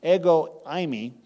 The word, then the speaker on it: ego eimi: He used the phrase, which is in the Greek, is ego eimi. That's how it's pronounced.